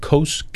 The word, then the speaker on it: Kos: Kos, I think you pronounce it right. Kos. It's okay.